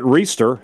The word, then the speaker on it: Reister: Jarrett Reister, who says his last name is pronounced like Easter with an R in the front of it.